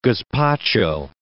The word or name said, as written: gazpacho